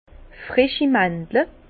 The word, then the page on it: freschi Màndle: Bas Rhin; Herrlisheim